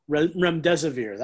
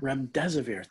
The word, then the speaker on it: Remdesivir: Remdesivir. – That's it. Thank you. I wouldn't be able to pronounce it. What is it again? – Remdesivir.